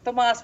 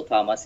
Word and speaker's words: Tomas: Well, Tomas works, but some people call me, just say Russell Thomas is fine.